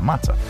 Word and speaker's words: matzah: It was a mix of nuts, apples, spices, and wine in between two pieces of matzah. I pronounced that right, right? – Matzah. – Yeah, matzah. And those are your five random facts.